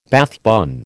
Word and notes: Bath bun: American English

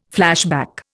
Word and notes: flashback: Indian

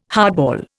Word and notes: hardball: Indian

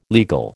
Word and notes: legal: American English

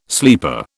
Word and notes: sleeper: British English

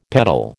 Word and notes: petal: American English

